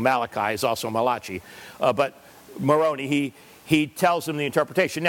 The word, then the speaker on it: Moroni: And then the angel Moroni, rhymes with macaroni, so it must be Italian, the angel Moroni, of course, he's not the only Italian angel.